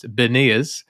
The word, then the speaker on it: Beniers: The only thing that was great about the 5-3 away game was that was the first game for and I've been calling him Matty Bernier, but I've realized that I'm pronouncing that entirely incorrectly, and it's Beniers.